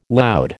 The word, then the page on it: loud: American English